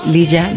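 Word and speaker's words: Lya: Lía es la forma correcta de pronunciarlo. – No, se dice Lía. – Lía. – Pero se escribe Lía. – Lía. Ajá, para que la ubiquen todos ahí en las redes sociales, no le vayan a poner nada más Lía. Es L y latina Y A.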